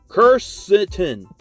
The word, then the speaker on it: Quercetin: Quercetin is how you say it.